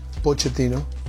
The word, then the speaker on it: Pochettino: How do you pronounce Pochettino? – No, so easy. Pochettino. Yes, Pochettino. Easy. And it's Italian name. In Italia, it's Pochettino. I like more Pochettino.